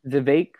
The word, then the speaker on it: Vivek: Vivek rhymes with cake.